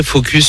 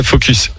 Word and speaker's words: focus: je pense que j'étais très focus sur une signature surtout. Prononce bien le S hein, à la fin de la phrase. très focus. Focus.